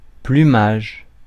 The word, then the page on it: plumage: [ply.maʒ]; France